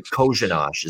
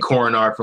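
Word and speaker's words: Kořenář: Kojanosh is how you say it. So there you go. So you wouldn't have known that. You look at it, it's Korenar. And somehow it's pronounced Kojanosh.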